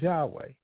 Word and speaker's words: Yahweh: which most people know of as Jehovah, and a lot of people are always talking about, you know, Jehovah Nisi, Jehovah, but really. The better translation or the better pronunciation is Yahweh.